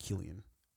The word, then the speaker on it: Cillian: Did you say it's like Cillian? That's what I want to say, or Cillian. That's how it's spelled, or at least that's how it looks to us Americanos. Yeah. But I believe it's pronounced Cillian.